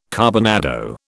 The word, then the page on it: carbonado: British English